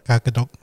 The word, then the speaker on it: kakatok: Is that right? I fucking said it wrong. – Kakatok.